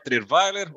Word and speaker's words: Trierveiler: Então, gente, meu nome é Fábio Trierweiler. Ó, oh, perfeita pronúncia, né? Isso é raro.